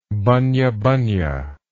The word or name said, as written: bunya bunya